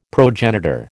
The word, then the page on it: progenitor: American English